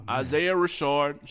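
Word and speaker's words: Isaiah Rashad: Oh, Isaiah Rashard.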